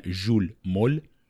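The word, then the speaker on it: Jules Mohl: تلفظ فرانسوی اسمش هم بهش میگن ژول مول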